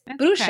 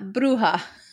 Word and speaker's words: Bruxa: I was pronouncing it like Spanish H, like the J, the H, but it's Bruxa, okay. Not Bruja.